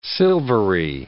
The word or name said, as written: silvery